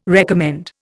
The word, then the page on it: recommend: Indian